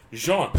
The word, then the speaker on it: Jean: Pronounce his name right so Cole can. – Jean. It's not what you wear.